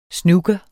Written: snooker

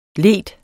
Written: leder

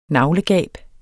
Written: naglegab

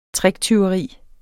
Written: tricktyveri

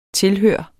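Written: TILHØRET

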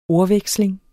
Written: ordveksling

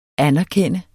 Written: anerkende

